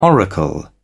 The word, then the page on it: oracle: British English